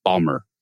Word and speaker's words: Baltimore: Or I'm sorry, if you're from Baltimore, I have to say like Baltimore, Balmer, right? If you pronounce Baltimore, you're not from there.